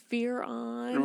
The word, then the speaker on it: Fearon: Because Theron. I'm Jamaican, so. – There we go. – Is Theron. It's Fear-on. – Right.